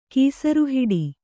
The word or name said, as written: ಕೀಸರುಹಿಡಿ